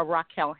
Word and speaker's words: Rakale: Yeah, so if they go to Kickstarter, just search Other Side of Blue or Rakale Hannah. My name is spelled R-A-K-A-L-E, last name Hannah.